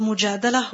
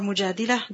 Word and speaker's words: المجادلة: Both are correct. The surah is called Mujadalah or Mujadilah